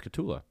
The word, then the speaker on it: Katula: No, it's Katula. – You sure? – I'm pretty sure the emphasis on tool.